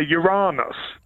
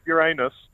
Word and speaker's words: Uranus: Well, Patrick Moore always used to say Uranus in a desperate effort to avoid the titters. But it is, I'm afraid, Uranus.